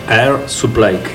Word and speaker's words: Air Supply: Air Supply. ¿Lo dije bien? – estás muy bien en pronunciation.